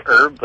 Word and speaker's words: herb: Like, well, the Americans say herb, like oregano and thyme and stuff like that. – Mm-hmm. – The Brits say herb with an H.